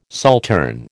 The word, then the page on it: saltern: American English